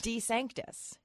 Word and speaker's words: DeSantis: Now, I do have to bring you in very quickly here because your maiden name is DeSantis. – Yes. I'm just one letter away from the governor's last name. – Very close. – Yes. – Is it DeSantis or DeSantis? – It's DeSantis. So he would be DeSantis.